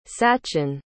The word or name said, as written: sachin